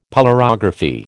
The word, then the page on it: polarography: American English